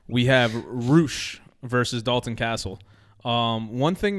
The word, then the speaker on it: Rush: Roosh. It's spelled Rush. It's R-U-S-H, but you pronounce it Roosh.